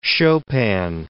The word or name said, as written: Chopin